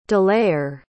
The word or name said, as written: delayer